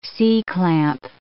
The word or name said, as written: C-clamp